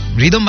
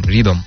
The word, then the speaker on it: রিদম: আমার নামের বানানটা হচ্ছে RYTHOM, RYTHOM রিদম।